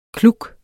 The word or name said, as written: kluk